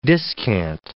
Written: discant

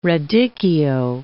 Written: radicchio

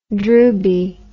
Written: drooby

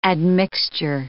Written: admixture

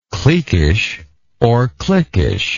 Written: cliquishness

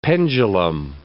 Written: pendulum

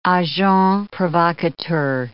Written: agent provocateur

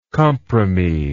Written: compromis

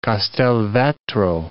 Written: Castelvetro

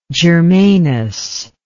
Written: germanous

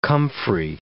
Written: comfrey

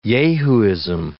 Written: yahooism

